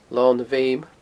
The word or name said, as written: Lon a Bheuma